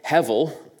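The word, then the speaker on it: hevel (הֶבֶל): Now that word is one more Hebrew word, hevel. It's got a B in the middle, but it's pronounced like a V. It's very similar to the word Abel, as in Cain and Abel.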